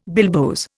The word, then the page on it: bilboes: Indian